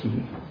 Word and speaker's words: Xi: 希特勒、斯大林、毛泽东 and current day, Putin.And how do you pronounce China's? Xi? – Xi? Xi. Xi 主席.